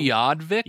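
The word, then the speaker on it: Utqiagvik: Ukiadvik? – Yes.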